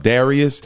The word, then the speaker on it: Dadrius: Yes, it is pronounced Darius. The second D is silent. That's D-A-D-R-I-U-S-L-A-N-U-S.